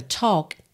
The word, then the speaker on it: talk: E o L dessa palavra talk também não é pronunciado. A gente não diz talk, fica talk, tá bem?